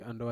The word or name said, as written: andowa